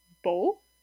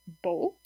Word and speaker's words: beau: So this would be a non-nasalized vowel in the word beau, beau, and then nasalized would be bon, bon. So in a nasalized vowel, nasal vowel, some of the air actually comes through your nose when you pronounce the vowel, not just through your mouth.